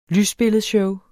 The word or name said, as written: lysbilledshow